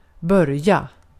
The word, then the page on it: start: American English; [stɑɹt]